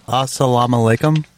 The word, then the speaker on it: Assalamu alaikum: Assalamu alaikum? – I believe so.